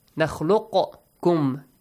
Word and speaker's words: nakhluqukum: There is the qaf. After the qaf, you have kaf. Since these two letters kind of like pronounce the same, the same articulation points they have, similar letters they are, okay? You merge them. Nakhluqukum. You don't pronounce the letter qaf.